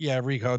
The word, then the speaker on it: Ricoh: Ricoh, that's fine.